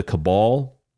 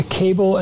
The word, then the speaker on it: cabal: We have the cabal.